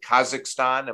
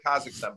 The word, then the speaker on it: Kazakhstan: I mean, Chris, it was interesting to watch Djokovic playing in watching him in the Davis Cup, and he's being interviewed after, tell me if I'm pronouncing this right, after they played Kazakhstan, have I got that right? – As far as I know, my Kazakh needs work, but yeah, okay, I got you.